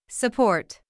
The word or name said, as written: support